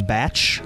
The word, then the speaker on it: Cumberbatch: Benedict Cumberbatch as Dr. Strange. – Cumberbatch? – Is that his name? – Batch. I'm pretty sure it's Batch.